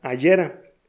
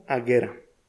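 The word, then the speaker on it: Agera: Luego de unos minutos de búsqueda te das cuenta que no se pronuncia Ayera, tampoco Ajera, queda como aguera.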